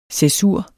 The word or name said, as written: cæsur